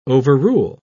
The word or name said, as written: overrule